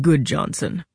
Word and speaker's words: Gudjonsen: My name is Eric Goodjohnson, spelled G-U-D-J-O-N-S-E-N, but pronounced Goodjohnson.